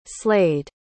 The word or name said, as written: slade